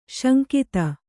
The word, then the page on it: ಸಂಘಾತ: kannada